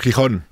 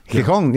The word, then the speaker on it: Gijón: Yeah, in Gijón, is that how you say it? – Gijón. – Gijón, yeah.